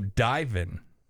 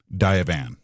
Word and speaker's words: Divan: So Divan is the proper way to say that. – Divan.